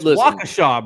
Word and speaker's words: Waukesha: I'm not even going to try to pronounce Waukesha. – It's Waukesha, bro.